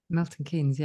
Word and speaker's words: Milton Keynes: Did it start at the what is now Milton Keynes? Is that how you pronounce it? – Milton Keynes, yeah.